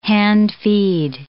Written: hand-feed

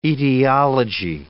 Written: etiology

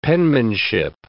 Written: penmanship

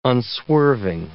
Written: unswerving

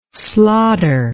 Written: slotter

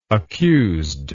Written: accused

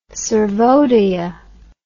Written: sarvodaya

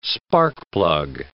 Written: sparkplug

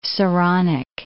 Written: Saronic Gulf

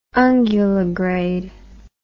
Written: unguligrade

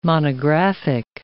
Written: monographic

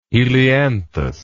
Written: helianthus

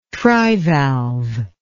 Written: trivalve

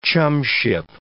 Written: chumship

